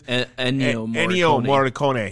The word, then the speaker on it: Ennio Morricone: Neo... Morricone or whatever the fuck his name is. – Ennio Morricone.